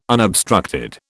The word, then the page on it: unobstructed: British English